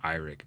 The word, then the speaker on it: Eirik: And I think it's actually pronounced Eirik.